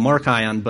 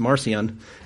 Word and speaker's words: Marcion: And if we look at this, in the second century AD, there was a guy named Marcion, or sometimes I call him Markion, but I believe it's pronounced Marcion.